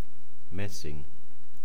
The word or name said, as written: maessing